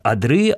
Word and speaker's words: одры: и ударение в этом слове всегда ставится на окончание, кроме именительного падежа, потому что здесь только один слог. То есть, если вам нужно сказать о множественном числе, то вы говорите «одры», «одрах», «водрах» и так далее, тому подобное.